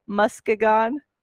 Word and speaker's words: Muskegon: And then we also had Lansing and tell me if I'm saying it right, Muskegon.